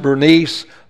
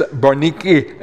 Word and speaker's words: Bernice: I pronounce it Bernice. The Bible pronounces Bernice. Some people pronounce it, if you pronounce it in the Greek tongue, it's Berniki and something like that.